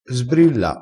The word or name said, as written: sbrilla